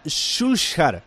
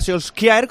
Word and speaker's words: Solskjær: Dato curioso: Solskjaer, el noruego, se pronuncia Solskjær. Dice: es que tengo un amigo noruego y hablando de él, con él del United, nombré a Solskjaer, como decimos muchos, y me dijo: ¿quién?